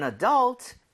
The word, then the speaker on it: adult: Alright, so one for an adult and one for a 12-year-old. Okay, 정답 맞습니다. 오. 성인이라는 단어, adult, right? – 네. – 자, 강세를요, U에다가 찍고 발음을 하면 좋습니다.